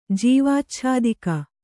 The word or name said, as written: ಜೀವಾಚ್ಛಾದಿಕ